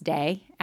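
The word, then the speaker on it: Te: I just finished reading The Tao of Pooh, T-A-O of Pooh, Pooh as in Pooh Bear, and The Tay of Piglet. I think it's pronounced Day, actually Tao and Day, so my apologies for mispronouncing.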